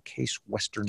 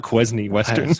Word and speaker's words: Case Western: It's not, okay. It's not Quesney Western.